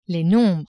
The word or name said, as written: nombres